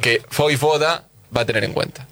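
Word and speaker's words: Vojvoda: Voivoda. – Que no. – Sí. – Sí, porque la J se pronuncia ahí.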